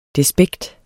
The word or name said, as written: despekt